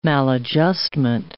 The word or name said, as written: maladjustment